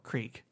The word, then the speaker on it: creek: How do you say the word where like a little stream runs? – Oh, creek. – Yeah. – But people around here say crick. – If you say crick in my presence, I mock you publicly.